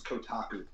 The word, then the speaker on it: Kotaku: And the site's pronounced Kotaku.